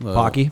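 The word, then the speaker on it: Paqui: Maddie cannot find the, how do you say it? – The Pocky chip. – Pocky? – Yep, one chip challenge.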